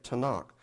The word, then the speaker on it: Tanakh: would be a T and an N and a K. And then you fill in the vowel sounds, you get Tanakh.